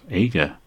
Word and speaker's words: Ager: Another name that's often mispronounced is my surname, which I pronounce Ager, and it's spelt A-G-E-R. Now, often when you have a G followed by an E, it's pronounced J, so many people pronounce it Ager, Aga, or Arja, or some other variation.